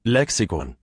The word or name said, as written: Lexikon